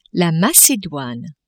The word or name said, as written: Macedoine